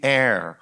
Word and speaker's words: heir: to be the heir. Heir. H-E-I-R. It is pronounced air, just like what we breathe, but heir, H-E-I-R, means one who inherits.